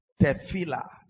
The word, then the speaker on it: tefillah: The Hebrew word is tefillah. Now, not tehillah now. Tefillah.